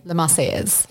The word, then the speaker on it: La Marseillaise: And then the French anthem, can you say it for me? – La Marseillaise. – Okay. Thank you. Because I don't want to butcher that.